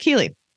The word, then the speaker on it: Ceilidh: It's spelled Ceilidh. – Ceilidh? – Nope, just D-H. – D-H. No, I haven't. – Okay, well, that's pronounced Ceilidh.